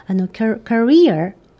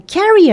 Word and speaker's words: career: あとね、 3 パラ目で、ちょっと発音、アクセントに気をつけておきたいかなっていうのが、 career、これですね、経歴、キャリアね、はい、 carrier、はい、って前にアクセントを持ってきてしまうと、運ぶものとかね、はい、いう言葉になるので、 aircraft carrier とか言うと空母とかになってくるので、はい、アクセントに、後ろのね、 EE のところに持ってくるようにしておきましょう。